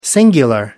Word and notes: singular: American English

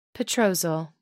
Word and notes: petrosal: American English

